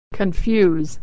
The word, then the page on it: confuse: y